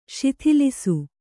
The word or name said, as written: ಶಿಥಿಲಿಸು